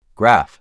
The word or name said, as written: Graph